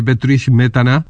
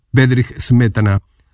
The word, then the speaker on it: Bedřich Smetana: o canciones de los checos de Bedřich Smetana, o como se le suele pronunciar en países hispanos, Bedřich Smetana.